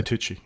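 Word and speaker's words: Tucci: has got a Stanley, and I never can pronounce the last name, TOOOCHI, TOOOCHI? – Stanley TOOOCHI, yeah.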